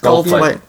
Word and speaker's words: Goldthwait: How do you say that? Goldthwait? – Goldthwait. – Yeah, I think that's pretty close. – Goldthwait.